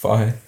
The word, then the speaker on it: Fage: F-A-G-E. – Is that how it's pronounced? – Fahe, yeah.